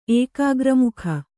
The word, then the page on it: ಏಕಾಗ್ರಮುಖ: ēkāgramukha